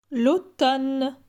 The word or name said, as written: L'automne